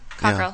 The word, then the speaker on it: Cockerell: Is that how you'd pronounce it? – Yep, Cockerell.